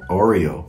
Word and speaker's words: Oreo: Aqui é com óleo só, só. O óleo é bem popular aqui no sorvete do McDonald's. – Não é óleo, como que é pronúncia em inglês correta? Oreo.